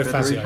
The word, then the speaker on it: Fazio: Oh, Fassier. Federico Fazio. I pronounced it wrong.